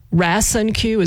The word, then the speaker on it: Rasonque: So again, RasinQ is how you pronounce it. R-A-S-O-N-Q-U-E is the spelling of that. So if you are dealing with that, someone you know is dealing with that, you might want to ask your doctor about it.